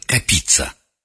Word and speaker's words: Капица: Петр Капица. Иногда его фамилию произносят с другим ударением. Петр Капица.